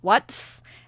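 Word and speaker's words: Wurts: So, our Music Corner this week was What's... And it's spelled W-U-R-T, capital S. And Watts is a solo artist that got a start in 2021.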